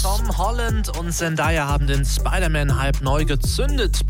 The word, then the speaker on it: Zendaya: Nein, Zendaya. Also so wird's Englisch ausgesprochen. Zendaya.